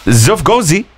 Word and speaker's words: XFGOLSZZY: A mother's attempt to register her daughter under the name XFGOLSZZY, pronounced Zufgozi, sparked a viral debate after hospital staff reportedly refused to accept it.